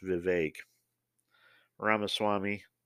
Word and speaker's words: Vivek Ramaswamy: You know, I mean, my guy, Vivek, I'm sorry, I found out last night watching Gutfeld that it's pronounced Vivek Ramaswamy.